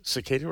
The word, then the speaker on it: cicada: Starting with a soft C. Yes, cicada, right? Isn't that right? – Cicada, yeah.